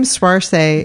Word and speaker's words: Saoirse: I had to take a picture and text Chris, because the name Swarse is hard to pronounce. I'm probably not even pronouncing it correctly. It's spelled S-A-O-I-R-S-E.